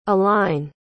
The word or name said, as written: A-line